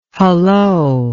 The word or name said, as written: halloa